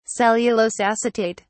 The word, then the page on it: cellulose acetate: British English